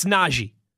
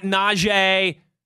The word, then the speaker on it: Najee: Folks, that's not how he pronounces his first name. He's been very clear about this. It's Najee. Najee Harris, all right? Not Najee.